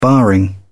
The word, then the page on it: barring: British English